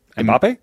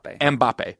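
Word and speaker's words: Mbappé: Mbappe? – Yes. – Yes. – Can you say it one more time? – Mbappe.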